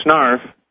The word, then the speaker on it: Snarf: That was my medical school nickname, Snarf.